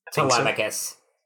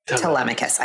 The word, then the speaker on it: Telemachus: And I love that you used the word restraint, because it's also to, how do you say Tom Holland's character, Temeculus? – Telemachus. – Telemachus. I always, get those two. Telemachus.